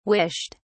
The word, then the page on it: wished: British English